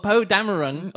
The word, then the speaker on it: Poe Dameron: And I was more upset about the hypothetical death of Poe Dameron.